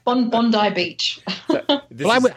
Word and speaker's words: Bondi: By the way, nice pronunciation of Bondi. Most Americans think it's Bondi.